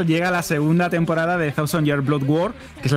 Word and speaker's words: Thousand Year Blood War: Thousand Year Blood War. ¿Lo he dicho bien? Perfectamente, te ha faltado decirlo en japonés.